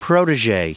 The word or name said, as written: protege